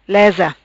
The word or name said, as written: Lesa